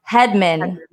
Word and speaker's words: Hedman: And thank you, James Flash Ludeman, for tuning into the show and correcting me on my pronunciation. It's pronounced Hedman.